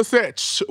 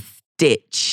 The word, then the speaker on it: sitch: Oh, you said it right. Because Denzel for this past has been saying, what's the stitch. – I always thought it was, what's the stitch? – He forgot. It's situation, girl.